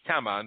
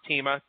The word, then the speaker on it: Tema: But I can tell you that her first name rhymes with Emma. It's Tema, not Tima, Tema.